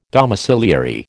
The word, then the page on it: domiciliary: American English